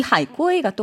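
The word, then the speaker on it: 하이 꾸에이 (海归): 그러니까 해외에서 공부하고 혹은 이제 해외에서 이제 일하고 돌아온 사람들을 말하는데 해외 유학파 정도로 보시면 되는데 이 하이 꾸에이가 또 발음이 바다 거북이하고 똑같아요.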